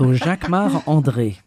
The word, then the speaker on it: Jacquemart-André: Jacquemart-André. You say it like André. – A Frenchman. – Jacquemart-André. Of course, because I'm French. So Jacquemart-André.